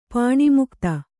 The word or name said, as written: ಪಾಣಿಮುಕ್ತ